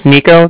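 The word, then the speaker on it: Niko: So, yes, my name's Nico. That's N-I-K-O.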